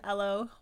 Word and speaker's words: Elo: I'd say Elo. Because it looks like Hello, but without the H. – Yeah, it's an Elo water bottle.